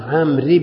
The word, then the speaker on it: عمرو: قال وعن عمرو بن شعيب، بعض طلاب العلم ينطقون عمرو بالواو والضم ومدريش، هذا الكلام مو صحيح هنا. وعن عمرو بن، والواو هذه يؤتى بها للتفريق بين عمرو وعمر، وإلا تأتي عليه الحركات كلها. اسمعوا، يتحدث عن شخص عمرو، ما بصحيح، ما بعمرو وعمر. رأيت عمرا، مررت بعمر، فالواو إنما لا تنطق، إنما يؤتى بها للتفريق بينه وبين عمر.